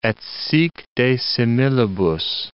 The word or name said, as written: et sic de similibus